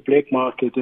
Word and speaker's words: Blacqmarket: Okay, firstly, it's spelled B-L-A-C-Q. So it's Q instead of K, so it's Blacqmarket.